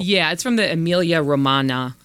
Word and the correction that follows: Emilia Romagna: uh, I hope I didn't butcher that pronunciation, uh, the Emilia Romagna uh, region of Italy.